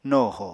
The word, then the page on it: no ho: Apatani